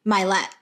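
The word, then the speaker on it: Mylett: Oh, is that how you pronounce his last name? – Milet.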